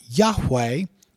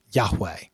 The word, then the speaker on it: Yahweh: Now, significant Jewish and Christian scholarship generally agree that the sacred name would have been closely pronounced as Yahweh in Moses' day.